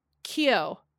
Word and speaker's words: Keough: Like, if Riley Keough, that's how you pronounce her last name.